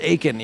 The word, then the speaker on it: Aiken: They're like, no, it's just Aiken, you know.